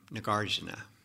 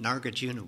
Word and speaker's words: Nagarjuna: And about 500 years later, around 100 CE or 100 AD, in southern India, a man named Nagarjuna, you British call him Nagarjuna, but I ask an Indian how to pronounce his name. A man named Nagarjuna was born at the foot of an Arjuna tree.